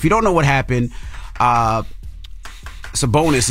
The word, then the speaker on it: Sabonis: Now, that's for stomping, I guess you can say, on Sacramento Kings Domantas Sabonis.